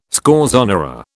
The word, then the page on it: scorzonera: British English